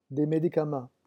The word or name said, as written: des medicaments